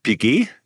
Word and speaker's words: Piguet: Die Marke wurde 1875 gegründet von Jules-Louis Audemars in Zusammenarbeit mit Edward Auguste Piguet.